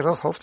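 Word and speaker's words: Roodhooft: A mí me gusta mucho cómo está llevado el equipo de los hermanos Rojo, siempre lo digo mal. – Sí, Rojhoff.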